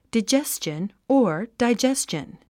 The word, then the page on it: digestion: daidʒéstʃən; ダイジェスチョン